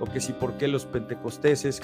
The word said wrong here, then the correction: pentecostales: que no se dice pentecosteses, se dice pentecostales, eh, eh, hacen un uso de los dones.